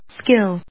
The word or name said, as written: skill